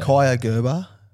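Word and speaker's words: Kaia Gerber: How would you say it? – Kaya Gerber. – Kaya. Yeah, that's 100% yeah, that right. Good. Yeah, I stuffed that.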